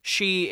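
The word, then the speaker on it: She (四): She is Japanese 4. That's how it's pronounced in Japan.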